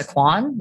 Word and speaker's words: Kwan: Melissa Kwan, last name is spelled K-W-A-N.